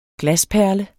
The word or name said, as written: glasperle